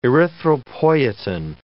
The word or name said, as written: erythropoietin